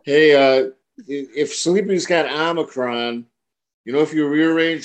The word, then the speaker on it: Omicron: It's pronounced Omicron.